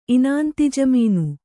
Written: ಇನಾಂತಿಜಮೀನು

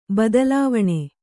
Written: ಬೈತಲೆವಣಿ

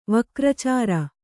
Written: ವಕ್ರಚಾರ

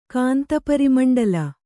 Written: ಕಾಂತಪರಿಮಂಡಲ

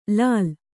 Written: ಲಾಲಿ

